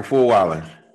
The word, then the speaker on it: Fulwiler: I say it right, Michael Fulwiler.